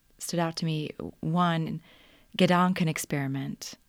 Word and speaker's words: Gedanken: Well, you said it pretty accurately. Gedanken experiment is, I mean, how I would say it. I think the general rule is when you take a foreign word, you pronounce it like you would in English. But I think that is the German pronunciation.